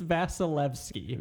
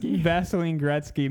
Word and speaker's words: Vasilevskiy: I think it's Vasilevskiy.